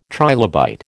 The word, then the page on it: trilobite: American English